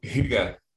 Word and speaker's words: hygge: So there's a Danish word called hygge. It's spelled H-Y-G-G-E, sometimes translated as like coziness.